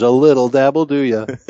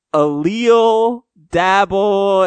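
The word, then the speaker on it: a little dab'll do ya: And so someone wrote in as that, and you pronounced that as? – Well, I thought it was the guy's name or whatever, and he's like, I was like, a leal dabble, and then you guys just lost it.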